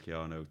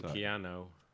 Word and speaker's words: Keanu: I was going to correct his pronunciation of transmitting. – Transmitting. – And Keanu. – Yeah, it didn't roll off the tongue as well as it should have. – I mean, it's ooh, not oh.